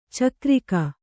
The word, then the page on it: ಚಕ್ರಿಕ: cakrika; Kannada